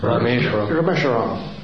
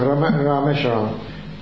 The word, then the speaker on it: Rameshwaram: Rameshwaram. – I pronounced it wrong. Rameshwaram.